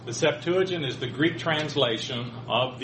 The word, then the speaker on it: Septuagint: We talked last week about the Septuagint, or you could pronounce it Septuagint, or various pronunciations.